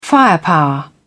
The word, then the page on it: firepower: British English